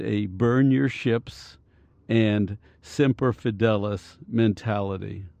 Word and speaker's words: Semper Fidelis: I think it's Semper Fidelis.